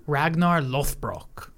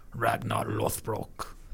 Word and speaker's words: Ragnar Lothbrok: Ragnar Lothbrok. I'm saying how they say it. Ragnar Lothbrok.